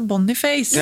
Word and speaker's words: Boniface: Vi fick ju också besked av vi var I Molde på kamp där så kom OI bort och bara Han från Nigeria, där uttalas det Boniface.